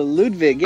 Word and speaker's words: Ludwig: Is it Ludwig von Drake? – Oh, Ludwig, yeah.